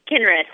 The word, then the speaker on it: Kinry: Marni, I don't know how to say your last name. Marni Kinry? – It's Kinry. I don't even know how to say my last name sometimes.